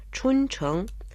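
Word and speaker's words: Чунчэнг (春城): Чунчэнг. Чун означает «весна», произносится в первом тоне, а Чэнг – это город, произносится во втором тоне.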